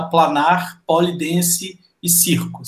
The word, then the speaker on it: Planar Pole Dance e Circo: você é fundadora e também é uma das professoras da Planar Polidense e Circo, certo? Eu falei certo? – É isso mesmo.